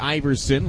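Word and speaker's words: Aeverson: Now, his first name pronounced like Iverson, like Allen Iverson, a native of Venezuela, is the son of a former professional basketball player in South America. And yep, the timing matches up. Not spelled the same, but pronounced the same.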